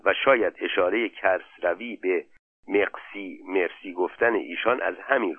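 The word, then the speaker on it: merci: مقسی گفت همون کلمه مرسی فرانسه با تلفظ فرانسه.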